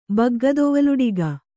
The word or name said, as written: ಬಗ್ಗದೊವಲುಡಿಗೆ